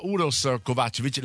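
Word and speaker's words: Uroš Kovačević: Allora, grazie a Uros Kovacevic, la pronuncia è giusta, eh? – Perfetto.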